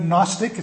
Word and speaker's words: Gnostic: The word Gnostic, it's got a silent G, Gnosticism.